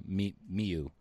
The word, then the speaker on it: Miyu: So when I picked it, it's, so the movie OVA we're reviewing is Vampire Princess Miyu, Miyu, that's how you pronounce the name.